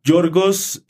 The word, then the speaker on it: Yorgos: Yorgos, el yogur griego, Lantimos.